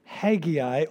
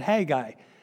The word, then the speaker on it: Haggai: And this is my favorite minor prophet that we're going to look at, and his name is Haggai, or some people pronounce it Haggai. Either one is good. I just happened to learn, and I call him Haggai.